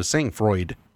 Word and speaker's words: sang-froid: So I'm pronouncing this Sangfroid. – Okay. – If this was French, it'd be sang-froid, I believe, which is cold-blooded.